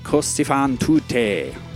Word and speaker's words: Così fan tutte: I'll just say Così fan tutte.